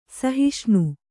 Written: ಸಹಿಷ್ಣು

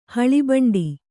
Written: ಹುಲಿಬೆಂಡು

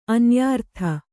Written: ಅನ್ಯಾರ್ಥ

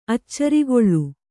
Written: ಅಚ್ಚರಿಗೊಳ್ಳು

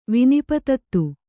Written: ವಿನಿಪತತ್ತು

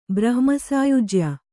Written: ಬ್ರಹ್ಮಸಾಯುಜ್ಯ

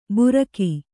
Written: ಬುರಕಿ

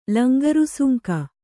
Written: ಲಂಗರುಸುಂಕ

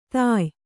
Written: ತಾಯ್

